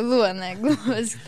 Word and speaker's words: Luana Glumbowsky: Tak, no Luana Glumbowski, tak dobrze.